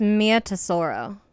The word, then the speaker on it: Mia Tesoro: I believe it's Mia Tesoro. – That's what I thought too.